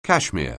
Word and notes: cashmere: anglais

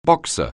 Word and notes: boxer: anglais

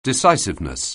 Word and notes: decisiveness: anglais

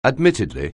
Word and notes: admittedly: anglais

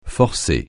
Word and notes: forcer: francais

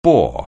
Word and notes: boar: anglais

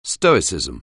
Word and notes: stoicism: anglais

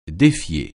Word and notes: défier: French

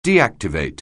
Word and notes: deactivate: anglais

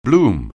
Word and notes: bloom: anglais

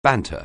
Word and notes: banter: anglais

